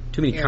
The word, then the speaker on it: AirPlay: Apple Play. All right, did I say that right? There's too many AirPlay. Constant AirPlay. Thank you. At least it's too close and it's still wrong.